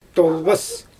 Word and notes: toRbas: Xyzyl

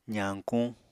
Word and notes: zañkuñ: apatani

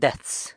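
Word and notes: dets: San Lucas Quiaviní Zapotec

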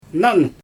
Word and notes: nan: Xyzyl

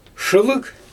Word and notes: ʃɯlɯɣ: Xyzyl

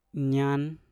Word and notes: nyan: Mpur

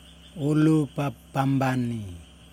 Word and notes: ulupapambani: Yokoim

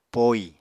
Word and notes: poi: Apatani

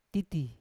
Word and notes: titi: Yawa

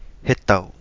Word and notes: hetau: Kapingamarangi

